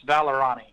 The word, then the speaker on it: Vallorani: It's Vallorani.